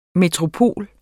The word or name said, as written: metropol